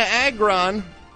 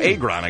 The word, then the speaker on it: Agron: Diana Agron. Ooh. Or Agron, I guess it's actually pronounced. I've been saying it wrong all this time.